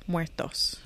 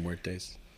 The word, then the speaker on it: muertos: Los muertos. Yeah, Los- I've been saying muertes. No. – 'Cause- Muertos.